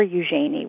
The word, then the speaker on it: Eugenie: It would come out as Eugene, Eugenia, and it was never Eugenie, which is really how my name is pronounced.